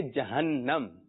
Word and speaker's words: جہنم: جی درست تلفظ ہے جہنم.